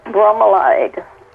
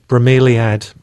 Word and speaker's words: bromeliad: I used to pronounce bromeliad, bromelade. What's the right way to pronounce it? – It's just straightforward, bromeliad.